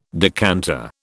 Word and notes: decanter: British English

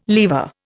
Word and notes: lever: Indian

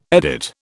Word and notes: edit: British English

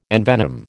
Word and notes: envenom: American English

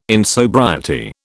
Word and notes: insobriety: British English

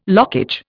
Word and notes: lockage: Indian

